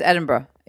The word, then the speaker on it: Edinburgh: you don't pronounce the Berg. It's just Edinburgh. You just don't pronounce it at the end.